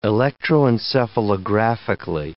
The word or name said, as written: electroencephalographically